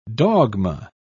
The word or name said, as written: dogma